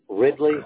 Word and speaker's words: Riddley: All right, Jamie, J-A-M-I-E, Ridley, R-I-D-D-L-E-Y. Two D's.